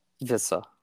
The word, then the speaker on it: Vissa: Vissa. – Naresh Vissa. Am I saying that right or close enough? – Nope, that's perfect.